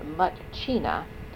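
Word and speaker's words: Myitkyina: She says she got, quote, upcountry, unquote, into the wilds of Nitkina, pronounced Mutchina, and Kunming, China.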